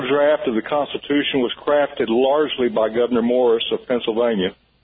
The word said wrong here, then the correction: Gouverneur Morris: It's actually pronounced Gouverneur Morris, and you are absolutely correct.